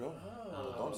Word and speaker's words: Dordogne: Dordogne.